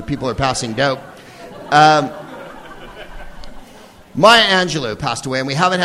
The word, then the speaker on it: Angelou: In any case, Dr. Angelou, Angelou, as she pronounced her name.